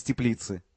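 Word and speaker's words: Теплице: Я для него рассаду помидоров привез из теплицы. – Теплицы. Ударение на первый слог. Это город такой в Чехии.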